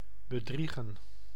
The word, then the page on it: bedriegen: [bə.dɾiː.ɣəː]